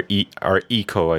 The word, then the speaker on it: Ico: I preferred Ico myself. – I never played that one. – Or Ico, I think, is the actual correct pronunciation.